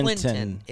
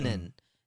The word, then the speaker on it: Clinton: It's not Clinton. Clinton. – It's Clinton. Clinton. There's no T. It's Clinton.